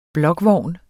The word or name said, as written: blokvogn